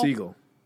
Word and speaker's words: Segel: Segel.